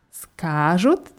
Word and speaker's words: скажут: Скажут. Die Betonung ist auf dem A.